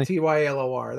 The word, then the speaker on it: Tylor: What do you think of Tyler McGill? I think it's Tylor, right? They pronounce it differently. – Tylor. That's right for me.